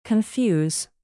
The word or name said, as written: confuse